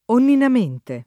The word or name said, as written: onninamente